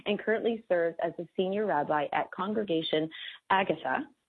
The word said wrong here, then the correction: Agudah: Oh, goodness. I'm so sorry, Rabbi. – That's okay. Agudah.